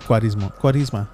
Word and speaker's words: Cuaresma: Cuaresma.